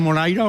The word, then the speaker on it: Moraira: ¿Lo he dicho bien? ¿Veraneaba usted en Moraira o no? – Sí, lo ha dicho usted exactamente como es.